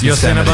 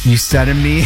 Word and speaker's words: Yosemite: You didn't say it right, but we know what you meant. Yeah. Yosemite.